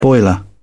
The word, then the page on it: boiler: British English